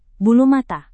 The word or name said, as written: bulu mata